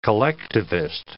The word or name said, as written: collectivist